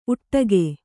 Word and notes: ಉಟ್ಟಗೆ: Kannada

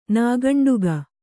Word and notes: ನಿಃಕಂಟಕ: nih kaṇṭaka

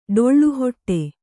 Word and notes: ಡೊಳ್ಳುಹೊಟ್ಟೆ: Kannada

